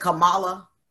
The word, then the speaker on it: Kamala: And I don't want to pronounce her name wrong. You said Kamala. Kamala. Yeah.